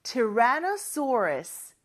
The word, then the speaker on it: Tyrannosaurus: 일단, 티라노사우루스는 Tyrannosaurus. 이렇게 영어로는 발음합니다.